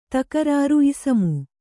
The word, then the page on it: ಇಸಮು: Kannada